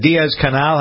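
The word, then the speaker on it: Díaz-Canel: Is it Diaz Canal? How do we pronounce this guy's name? Who's replacing Castro in Cuba? – You just did it.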